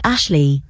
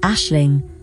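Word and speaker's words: Aisling: She says, Ashley, you think, but when you repeat it she corrects you. She has to say it twice more before it dawns that what she's saying is Ashling, only she's pronounced it the Irish way, correctly.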